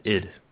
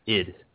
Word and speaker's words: id: The ones who make Doom, Quake, and all that, and Wolfenstein, it's pronounced id. – People say ID, don't they? – It's not right. It's not an acronym. There's no periods in between those letters. It's id, after Sigmund Freud's id, the thing in the brain.